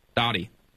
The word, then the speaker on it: Dottie: Dottie. Hey, you there? – Yes, yes, Dottie, D-O-T-T-I-E.